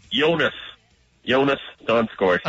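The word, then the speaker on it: Joonas Donskoi: How do you pronounce Donskoy's name? – You're going to test me right away with some stuff. I believe it's, I know it. It's Jonas Donskoy.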